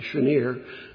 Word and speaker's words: Senir: And then he says, from the top of Senir, or it probably, my understanding is it's supposed to be pronounced with an S-H, Senir, the summit of Hermon.